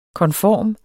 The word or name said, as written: konform